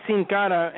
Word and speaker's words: Sin Cara: And, you know, as a Hispanic, I can pronounce that properly, which basically means without a face. But they're going to call him Sin Cara or whatever it is and, you know, just butcher his name left and right.